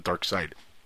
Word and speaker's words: Darkseid: Yeah, for some reason, it just looks like Darkseid, then Dark Side. You know, S-I-D-E, that's side.